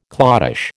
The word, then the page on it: cloddish: American English